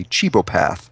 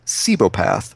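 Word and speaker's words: cibopath: He's Chu, C-H-U, and he chews things. And up until I looked this up today, I was pronouncing what he is as a chebopath. But when I looked it up today, I guess Wikipedia told me it's pronounced cebopath.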